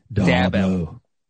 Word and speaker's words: Dabo: How do you say it, Breach? You said it wrong the other day. Dabo Sweeney? – Dabo.